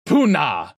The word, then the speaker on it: Poonah: They called the net the Poon-Ah. – Why would they call it that? Why would they do that? Poon-Ah? – Poon-Ah. – Spell it. – P-O-O-N-A-H.